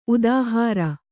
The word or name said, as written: ಉದಾಹಾರ